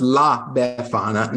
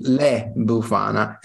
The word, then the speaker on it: La Befana: Oh, it's La Befana. – I know, that's the whole point. Is I that time I did it on purpose, but okay. I know it's La Befana now, but I keep saying Le Bufana, and it's not Bufana, and it's not Le Bufana.